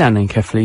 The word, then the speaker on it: anencephaly: Yes, this is anencephaly, you're right, or anencephaly. That's absolutely spot on for pronunciation.